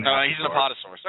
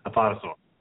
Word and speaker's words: Apatosaurus: He's an Aptosaurus is what he is. – He's an Apatosaurus. Okay. – Are we both saying the same thing but pronouncing it differently? – Yes. – Apatosaurus.